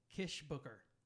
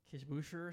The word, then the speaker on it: Kishbooker: It's Michael Kishbooker. – How did you think it was? – Kishbusher or something. So, but it's very German. So it's going to Kishbooker, you know, like kind of thing.